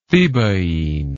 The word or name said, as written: thebaine